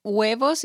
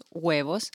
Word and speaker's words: huevos: Because it's huevos, it's with H. The H in Spanish doesn't sound, so just huevos.